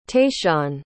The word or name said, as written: tayshaun